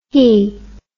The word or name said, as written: hee